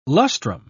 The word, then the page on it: lustrum: British English